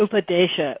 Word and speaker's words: Upadesha: So it's UPADESA. Upadesa. – Upadesha. Upadesha. Yes.